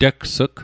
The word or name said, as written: tegs'g